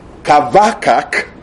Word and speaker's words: Habakkuk: His name Habakkuk, pronounced in Hebrew as Kavakak, means embracer or wrestler.